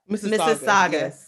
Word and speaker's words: Mississauga: Mississauga.